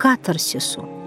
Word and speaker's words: катарсису: главной целью которой было привести душу читающего к катарсису. Напомню, что это греческое слово обозначает возвышение, очищение и произносится именно так, с ударением на первом слоге.